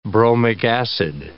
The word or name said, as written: bromic acid